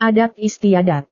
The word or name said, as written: adat-istiadat